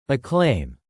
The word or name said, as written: acclaim